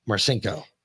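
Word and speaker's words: Marcinko: It's not, it's spelled weird. It's M-A-R-C-I-N-K-O. N-K-O. Yeah. Marchenko.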